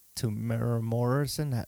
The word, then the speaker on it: Temuera Morrison: Right at the end, and you see Temuera Morrison.